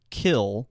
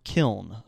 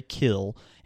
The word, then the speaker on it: Kiln: It's pronounced Kill, but it looks like Kiln. – Kiln. – So you say Kill, and it is the home of Brett Favre.